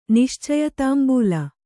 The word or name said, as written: ನಿಶ್ಚಯತಾಂಬೂಲ